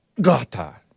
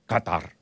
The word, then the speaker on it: Qatar: Qatar. Qatar. You're supposed to choke on it. Qatar.